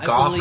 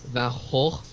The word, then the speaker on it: Van Gogh: I don't know if you call him Goff or Go. – I looked up how would he pronounce it in his own accent, I believe it was something like Van Hoch.